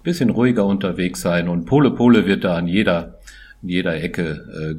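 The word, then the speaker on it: pole pole: und das heißt auf Swahilisch pole, pole.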